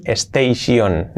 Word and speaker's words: station: T-I-O-N. Por ejemplo, para decir estación, station. No, station no. Station no. Station no. Esta terminación tion es shen, shen, como si fuera un sh, shen, shen, shen, y prácticamente una e, shen, shen.